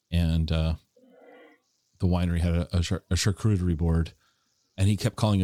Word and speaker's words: charcuterie: A good way to remember how to pronounce it, because the emphasis is on the right, you know, syllables, and you can kind of go Sean Connery charcuterie.